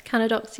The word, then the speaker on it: Cannadox: Canadox, yeah. – Canadox, okay.